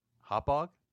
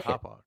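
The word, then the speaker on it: Hauppauge: How do you pronounce that? – Hop Hog? – Hop Hog? – Campy's from. – Okay. Sorry, I didn't know where Coach is from. And I certainly didn't know how to pronounce it, but Hop Hog.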